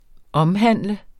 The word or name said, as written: omhandle